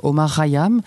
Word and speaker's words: Omar Khayyam: Alors on dit Omar Khayyam, comment le prononce-t-on ? – Oui, c'est ça, on dit Omar Khayyam, qui est un astrologue, mathématicien du XIIe siècle, un astronome perse.